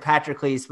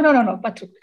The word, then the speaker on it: Patroclus: And before we get to Achilles and Patroclus, and maybe I'm getting, I've always said Patroclus, but my pronunciation of all this stuff is no, Patroclus. It's okay.